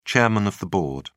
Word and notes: chairman of the board: British English

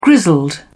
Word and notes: grizzled: British English

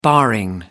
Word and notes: barring: American English

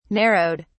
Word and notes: narrowed: British English

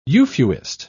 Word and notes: euphuist: British English

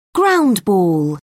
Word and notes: ground ball: British English

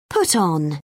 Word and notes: put on: British English

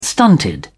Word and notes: stunted: British English

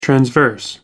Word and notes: transverse: American English

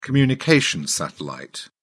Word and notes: communications satellite: British English

